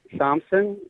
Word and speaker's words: Somsan: My name is Michael Sompson. It's spelled S-O-M-S-A-N.